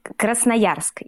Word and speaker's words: Krasnoyarsk: Then there is Krasnoyarsk. Do I say it correctly at all? – Krasnoyarsk, yeah.